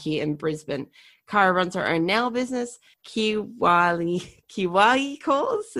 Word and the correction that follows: Kawaii: It's Hawaii, but with a K is how I tell people to pronounce it.